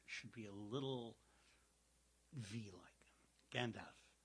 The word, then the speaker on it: Gandalf: So I said, what would that rhyme with? He said, well, like half a sixpence. Uh-huh. Half. Gandalf. But he pronounced the L. You could hear the L.